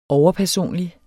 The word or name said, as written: overpersonlig